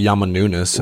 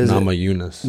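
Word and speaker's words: Namajunas: You can never say her name right. It's not Yama Nunes. It's Nama Yunus.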